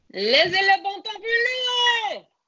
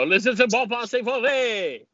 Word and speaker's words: Laissez le bon temps rouler: Laissez le bon temps rouler! – Yeah! – There you go. Laissez le bon temps rouler!